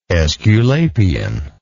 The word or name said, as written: esculapian